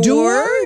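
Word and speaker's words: Doerr: en anglais, ce serait Anthony Doer. Doer. – Doer. The guy is a doer.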